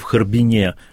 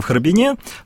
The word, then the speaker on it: Харбин: Поэтому историческое произношение в Харбине, современная норма русского языка говорит в Харбине.